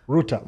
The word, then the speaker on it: Rutta: Ruta. – Ruta. – R-U-T-T-A.